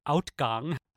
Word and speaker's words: uitgang: Ever since I spent time in the Netherlands, where the word for exit is U-I-T-G-A-N-G, and it's pronounced outgang, I've taken to pronouncing fruit Fruit.